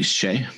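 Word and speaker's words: Hsieh: How do you say his name? Jason Tony Shea. Is that right? – You got it.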